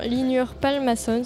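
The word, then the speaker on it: Hlynur Pálmason: Le film, c'est Un jour si blanc de, alors attention à la prononciation, Lynur Palmason, si je ne me trompe pas.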